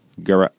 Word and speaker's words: Gerace: It rhymes with Durace. – Oh, sure. – Nice. – Yeah. – There we go. Not with dirigible as we thought. – No. – Gerace. – Wait, is that a soft G or a hard G? – It's not Gerace.